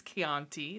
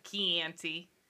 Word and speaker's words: Chianti: I was like, that's not how you say it. You know that. – You don't do it. – You know it's Chianti. It's not Chianti.